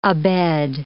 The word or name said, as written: abed